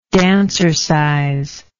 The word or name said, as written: dancercise